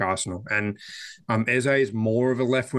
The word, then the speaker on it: Eze: Now, am I pronouncing it right? Is it easy? – No, it's Eze.